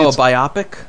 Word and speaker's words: biopic: If I hand you a piece of paper that says the word B-I-O-P-I-C... – Oh, you mean the pronunciation? – What do you call that? – A biopic. – Okay. Apparently, a biopic? – It's actually a biopic. I've been mispronouncing it.